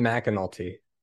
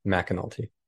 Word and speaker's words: McAnulty: It's Brian McAnulty. Okay. – So it's spelled M C A, but it's pronounced Mac McAnulty.